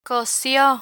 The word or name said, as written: cosio